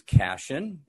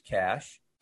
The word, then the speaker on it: Cashin: My website is Cashin, and it's spelled just like it sounds. Nothing tricky. C-A-S-H, Cashin. Mm-hmm. C-A-S-H-I-N.